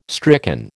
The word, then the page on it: stricken: American English